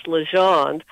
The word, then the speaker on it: Legendre: Well, let me first correct the pronunciation of her last name, and I pronounced it just like you did for the first year I was researching the book, and then I heard it pronounced correctly as Legendre.